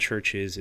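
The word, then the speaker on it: Chvrches: U in Churches is actually a V, but you still pronounce it the same.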